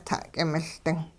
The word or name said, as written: ta'k'imiLding